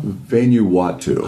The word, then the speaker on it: Vanuatu: What is it, Vanuatu? – Vanuatu. – I cannot pronounce it. – Yeah. – I keep trying. – You always got to stick the word Wat in there, and then you'll get it right.